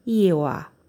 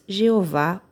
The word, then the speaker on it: Ieoá: Com os pontos, le deve ler-se Ieoá, mas também se diz Jeová ou Iavé.